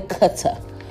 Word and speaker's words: Qatar: I am back from Qatar, or as they pronounce it out here, Qatar.